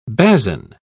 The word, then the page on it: basan: British English